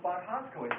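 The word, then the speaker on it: Boğazköy: And that was Boghazkoy here. – He discovered the Hittite capital. Boghazkoy is spelled B-O-G-H-A-Z-K-O-Y. Hittite is H-I-T-T-I-T-E.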